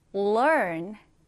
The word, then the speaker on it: learn: 읽을 때는요, 그냥 learn이라고 읽는 게 아니라 여기 R이 들어있잖아요. 그래서 learn. 혀를 안쪽으로 넣는다라는 느낌으로 learn 이렇게 읽어주시면 됩니다.